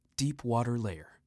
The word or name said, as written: Deep water layer